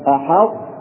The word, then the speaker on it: أحط: أحط يعني تنطق في بداية النطق الطاء ترتفع بها اللسان ثم تنطق التاء أحط وكذلك بسطت في قوله تعالى لئن بسطت إلي يدك.